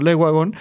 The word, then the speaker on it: Le Wagon: le pregunté a Mariana cuál era la pronunciación correcta, entonces me referiré a esta empresa todo durante todo el episodio como Leguagón.